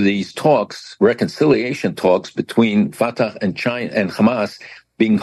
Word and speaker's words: Fatah: Now, the strangest story, Fatah and Hamas in talks in China. Did I say that correctly? – You did.